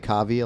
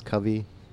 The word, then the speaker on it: Kavi: People call me Kavi a lot because my name is spelled K-A-V-I, but it's Covey.